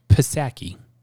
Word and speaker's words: Psaki: So, last thing relating to this, Jen Psaki. Although I might start saying Jen Psaki because the P is silent, like the P in President Joe Biden.